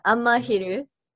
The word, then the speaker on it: Amahiru: So you got Amahiru. I hope I pronounced the band correctly. – Yeah, Amahiru. – Amahiru. – Yes.